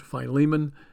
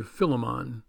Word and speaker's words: Philemon: Now, some people pronounce it Philemon and some people say Philemon.